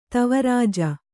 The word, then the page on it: ದೇವರಾಜ: dēva rāja